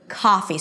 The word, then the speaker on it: coffee: One more thing, the k sound at the beginning of words before a vowel, since it's a stop sound that is voiceless, is aspirated. So it feels like there is a little H after. Coffee, coffee. So it's not coffee, coffee. Coffee. And the first syllable is longer than the second one.